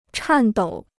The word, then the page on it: 颤抖: chàn dǒu